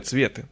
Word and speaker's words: цветы: То есть не цветы, а цветы, на первый слог ударения.